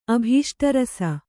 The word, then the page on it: ಅಭೀಷ್ಟರಸ: Kannada